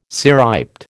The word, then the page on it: cirriped: American English